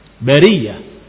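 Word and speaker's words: Bariyah (البرية): Taib, yang ma'ruf, yang dikenal dalam mushaf Utsmani, yang dipegang oleh kaum muslimin, Apa dibacanya? Bariyah, tanpa ada hamzah.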